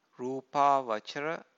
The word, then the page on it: rupavacara: Pali